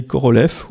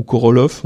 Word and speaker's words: Korolev: Sergei Korolev, ou Korolov, on peut prononcer les deux, je crois,